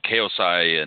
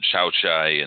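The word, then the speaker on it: Khaosai: Well, I was going to say, you know, I don't know if you're familiar, if you knew, but Kaosai and, or depending on how you pronounce it, Chow Chai and Chow Core Galaxy, the Galaxy brothers, they're twins, actually.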